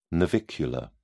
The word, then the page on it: navicular: British English